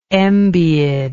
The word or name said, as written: Embiid